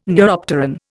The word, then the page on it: neuropteran: Indian